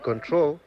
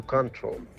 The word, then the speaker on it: control: No, porque la expresión es lose control, porque control, con el acento en la segunda O, es un sustantivo. Control, con el acento en la primera O, es el verbo. To control y control.